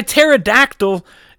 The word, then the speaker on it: Pterodactyl: First up, I've got a song by a band whose name begins with a silent P. Ooh. This one's by Pterodactyl. It's called Searchers.